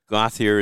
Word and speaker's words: Gauthier: How did I say it? Rob Gauthier? – That's French. – Okay. – Gauthier is English. – Gauthier. My bad.